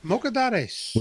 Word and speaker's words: Mocedades: I'm sure that's Mocedades 1973. – Yes, Mocedades.